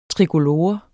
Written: trikolore

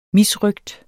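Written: misrøgt

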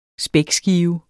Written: spækskive

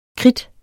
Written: kridt

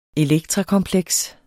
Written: elektrakompleks

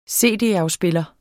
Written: cd-afspiller

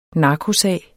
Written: NARKOSAG

